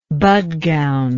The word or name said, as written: Bhadgaon